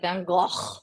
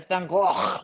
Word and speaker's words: Van Gogh: Yeah, but you have to say it Van Gogh. – Oh, really? We say it wrong over here? – Yeah, it's Van Gogh.